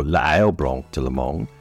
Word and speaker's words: l'ail blanc de Lomagne: White garlic of Lomagne, or l'ail blanc de Lomagne, as it is pronounced in French, is spelt ail, L apostrophe A-I-L, blanc, B-L-A-N-C, de D-U, Lomagne, L-O-M-A-G-N-E.